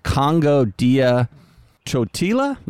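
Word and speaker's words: Kongo Dia Ntotila: The second album from a London-based outfit who I've never heard of before called Congo Dia Chotila. Is that how you pronounce that, Jim? – Maybe. – There's a silent N in there. – N-T starts off the word. – N-T-O-T-I-L-A.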